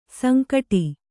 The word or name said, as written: ಸಂಕಟು